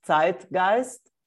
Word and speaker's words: Zeitgeist: It's a German word. The German language, in my impression, is hard. So it's Zeitgeist.